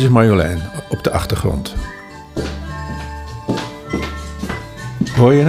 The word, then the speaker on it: Marjolein: Heeft ook de klemtoon op de laatste lettergreep. Gaat het naar boven. Marjolein. Niet zoals uh, ja, maar goed, in ieder geval, dit, en het, het, huppelt en het is, het is, het is leuk.